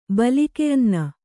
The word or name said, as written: ಬಲಿಕೆಅನ್ನ